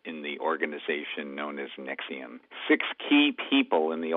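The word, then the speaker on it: NXIVM: There's an update on the Nexium case. That's spelled N-X-I-V-M. I'm not sure how you get Nexium out of that, but that's what it is.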